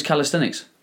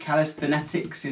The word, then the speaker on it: calisthenics: I was going to say, you passed the first test and you said it correctly. A lot of time people get into that middle section with the S and the TH and they stutter around it. – I've been to school, I did English. – Most people have, yeah, but still we struggle with that. Calisthenetics is often a common one and other various forms of mispronunciation.